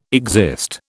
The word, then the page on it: exist: British English